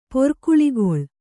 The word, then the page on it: ಪೋರ್ಕುಳಿಗೊಳ್: pōrkuḷigoḷ